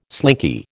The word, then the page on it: slinky: American English